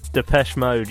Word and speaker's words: Depeche Mode: Depeche Mode, you did it.